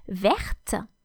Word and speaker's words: verte: Vert, and the feminine of this adjective is verte. Therefore, the masculine form has a final silent T, which you don't hear in the masculine, vert, but you hear in the feminine, verte. So, V-E-R-T or V-E-R-T-E for the feminine is the adjective green.